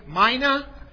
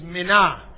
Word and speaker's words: mina: We like to say mina. In the Greek, actually, this currency is mina.